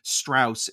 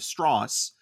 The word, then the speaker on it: Strauss: And there are little moments where anytime someone pronounces his name wrong, which is something in the movie, they say Strauss instead of Strauss.